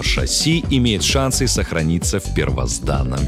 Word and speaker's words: шасси: Шасси. Ударение в этом слове только на последний слог.